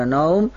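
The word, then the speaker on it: minan naum (من النوم): Ba'da hayyalataini, ketika mengucapkan, telah mengucapkan hayyalas salah, hayyalas salah, hayyal falah, hayyal falah, baru mengatakan as-salatu khairum minan naun, minan naum.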